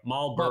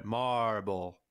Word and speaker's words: Marlboro: When you said it the first time, you said marble, but just like prolonged it. You went marble. Well, I say it like it's a Massachusetts town, Marlboro.